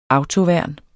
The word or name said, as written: autoværn